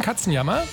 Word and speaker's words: Katzenjammer: Gleich bei uns die Nachrichten und anschließend ein bisschen Katzenjammer.